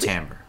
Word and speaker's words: timbre: Timbre. It's pronounced timbre. – Or timber.